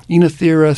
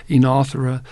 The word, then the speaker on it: Oenothera: Oenothera cespitosa. Some people say Oenothera. It's Oenothera cespitosa, no matter how you say it.